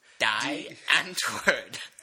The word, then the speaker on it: Die Antwoord: Die Antwoord.